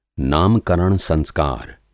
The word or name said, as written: नामकरण संस्कार